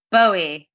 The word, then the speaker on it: Bowie: Is it going to be David Bowie, as we Americans say, or don't they say in England Bowie? – Bowie. Definitely Bowie.